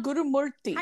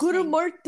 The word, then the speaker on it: Gurumurthy: Aruna Gurumurthy. – How do you say your last name? How do you say your last Gurumurthy. – Name? – Gurumurthy.